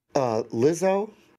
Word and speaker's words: Lizzo: Because, you know, all right, so there's a TV show right now, all right, going on, that is Lizzo.